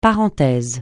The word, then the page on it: parenthèse: francais